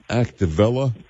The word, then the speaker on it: Activella: And I think the name was called Activella. Am I saying that correctly? – I believe so.